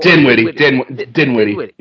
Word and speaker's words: Dinwiddie: Is it Dinwiddle? – Dinwiddie. You're right.